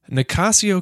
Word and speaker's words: Nicasio: Nicasio, California.